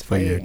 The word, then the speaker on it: Faye: Is it Faye? Faye? Yes, Faye, correct.